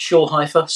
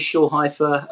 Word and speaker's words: Shoolheifer: Yeah, Instagram is just Shoolheifer, so it's the impossible but phonetic name to spell. So you can It's hard to pronounce unless you just read it, and then it's phonetic.